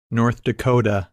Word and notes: North Dakota: English

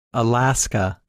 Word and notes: Alaska: English

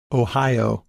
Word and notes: Ohio: English

